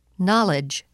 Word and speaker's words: knowledge: The letter K is not pronounced in the following words. Repeat them during the pauses. – No. New. Knowledge.